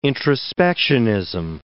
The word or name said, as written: introspectionism